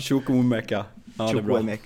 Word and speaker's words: Chukwuemeka: Shimovuka, egna produkten också. – Ja, just det. Du menar Chukwuemeka, ja, som vi fick in från Aston Villa. – Där satt uttalet.